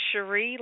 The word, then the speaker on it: Cheree: Yes, it is Cheree, like My Sharia Moore, the song.